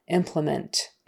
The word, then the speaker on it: implement: Implement, implementation, implement implementing, implementation, implement, implementing, implementation. For implement and implementing, these syllable stresses on the first syllable.